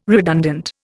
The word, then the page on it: redundant: Indian